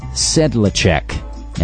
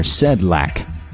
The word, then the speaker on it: Sedlacek: Originally from the Bellevue News-Democrat, Terry Sedlacek, and I've heard it pronounced some different ways, Sedlacek or Sedlak, S-E-D-L-A-C-E-K,